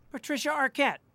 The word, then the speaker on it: Patricia Arquette: Somewhere Patricia Arquette has sensed that you have pronounced her name correctly.